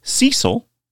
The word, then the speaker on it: Cesil: Well, if you do, Cecil has you covered. That's spelled C-E-S-I-L.